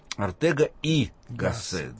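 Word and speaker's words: Ortega y Gasset: Ну, во-первых, Ортега и Гассет, да? Или Ортега и Гассет. Тут есть разные произношения, но, допустим, Ортега и Гассет.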